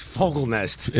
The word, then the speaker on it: Fogelnest: It's Fogelnest.